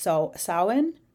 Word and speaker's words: Samhain: It's spelled Samhin, but it is pronounced Samhain.